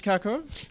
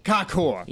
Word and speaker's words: Karkur: Tell me, is that how I pronounce Karkur? – Close, Karkur. – Karkur. – Yeah, a bit harsher, a bit more guttural.